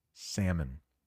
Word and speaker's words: salmon: So there are two ways to pronounce this fish in the United States, but the most common way is salmon. Silent L.